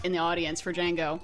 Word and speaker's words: Django: And she'd be like, it's Django. – Oh, the D is silent.